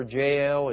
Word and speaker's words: Jael: And they fought the battle, and of course God gave them the victory, and we meet another lesser known woman than Deborah, Jael. And the way you can always remember Jael is her name rhymes with nail.